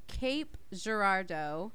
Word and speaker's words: Cape Girardeau: This episode takes place in Cape Girardeau, Missouri. Nice. I believe that's how it's pronounced. I looked up the pronunciation.